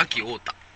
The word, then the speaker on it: 安芸太田: 秋太田。秋太田。これは、安い芸で秋って読む。